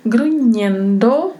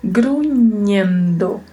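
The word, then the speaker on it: gruñendo: Grunhendo, que vem do verbo grunhir, e ele é escrito com N com tio em cima, na letra N, como falamos em espanhol, na letra N. Então a pronúncia precisa ter esse som semelhante ao NH do português.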